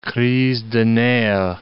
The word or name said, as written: crise des nerfs